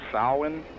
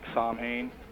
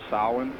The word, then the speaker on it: Samhain: It's pronounced Samhain. It sounds like, it looks like Samhain, but It's not. It's pronounced Samhain.